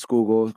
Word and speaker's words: Schuylkill: It's pronounced Schuylkill, which is the river in Philadelphia.